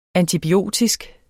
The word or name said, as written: antibiotisk